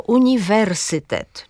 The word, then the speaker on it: uniwersytet: I ostatnie przykłady takiego akcentu na trzecią sylabę to pojedyncze rzeczowniki obcego pochodzenia. Na przykład właśnie mówimy prezydent, albo Rzeczpospolita, albo uniwersytet, czy maksimum i minimum.